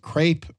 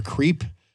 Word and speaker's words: crepe: For our listening audience out there, it's either pronounced crepe or creep.